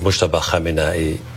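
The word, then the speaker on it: Mojtaba Khamenei: Okay, so it's Mujtaba Khamenei. Go back to the beginning. I got to get that pronunciation again. You got it. – That was beautiful.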